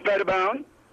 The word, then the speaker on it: Pettibon: It's Pettibon.